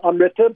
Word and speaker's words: Amrita: Am I pronouncing that correctly? – Yes, Amrita, but yeah, that's very close.